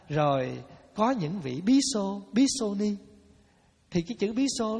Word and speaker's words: Bhikṣu: Mà Nam Phạn thì đọc là Bhikṣu, mà Bắc Phạn đọc là Bhikkhu.